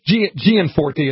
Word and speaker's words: Gianforte: But Gianforte is, I think, how you say it.